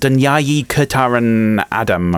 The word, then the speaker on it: Dünyayı Kurtaran Adam: It's called Dünyayı Kurtaran Adam.